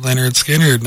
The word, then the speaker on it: Lynyrd Skynyrd: We also did Lynyrd Skynyrd, Simple Man, pronounced Lynyrd Skynyrd, 1973, Bad Company, Shooting Star, Straight Shooter, 1975, and then Thin Lizzy, The Boys Are Back in Town, Jailbreak, 1976.